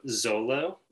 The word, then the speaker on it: Zolo: So yes, the band name is Trio Zolo. Though I should say that word is actually an Aztec word. And the language is Nahuatl, their language. So the traditional pronunciation would be more like Xolo. But for sort of our purposes, we are Trio Zolo.